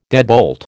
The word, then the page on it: deadbolt: American English